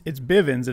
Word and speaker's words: Bivens: Is it Bivens or Bevins? – It's Bivens, and